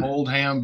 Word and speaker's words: Oldham: It's spelled Oldham, but we don't usually say the H.